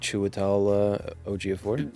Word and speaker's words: Chiwetel Ejiofor: You get a lot of Chiwetel Ejiofor.